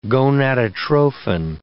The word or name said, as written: gonadotrophin